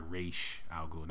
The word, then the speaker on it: Ra's al Ghul: And then in this case, when it comes to Christopher Nolan's version, he's actually trained by Ra's al Ghul, not Ra's, Ra's.